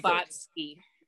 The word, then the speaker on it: Blavatsky: Blavatsky.